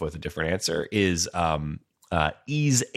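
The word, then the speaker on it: Ys: Ease 8. Lacrimosa of Dana. That's the series that's spelled Y-S, like big Y-S. Ease. Like the Joanna Newsom album.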